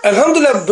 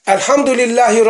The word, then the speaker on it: Elhamdülillahi: Elhamdülillah böyle mi okunur? Lam yok. Lam yok. Lam. Elhamdülillah Rabbil Alemin bak. Elhamdülillahi Rabbil. Orada cezim var. Yarım elif miktarı gösterilmesi lazım. Elhamdülillahi Rabbil Alemin.